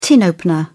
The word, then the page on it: tin opener: British English